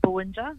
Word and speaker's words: Bowindja: Now, the Bowindja Connection, I hope I'm saying it correctly, you curated it. It features 17 titles, I believe. Can you tell us about this collection? – I believe you are saying it correctly, Bowindja Collection.